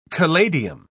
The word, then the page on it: caladium: British English